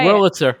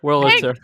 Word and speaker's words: Wurlitzer: But I also got, well, this is like thing of the week number two, but like I found a miniature Wurlizer jukebox. – Wow. Wait, what did you just call it? Did you say Wurlizer? – Wurlizer? How do you say Wurlitzer. – It? – Wurlitzer.